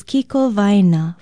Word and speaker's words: kikowaena: Aloha, I'm Leilani Poliahu of Hawaii Public Radio, and our Hawaiian word for today is kikowaena, for center.